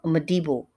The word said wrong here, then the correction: Mbedu: Mbedu. Let me say that correctly. Thuso Mbedu, Lashana Lynch, this Jamie Lawson, this is it.